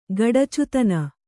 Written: ಗಡಚುತನ

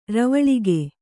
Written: ರವಳಿಗೆ

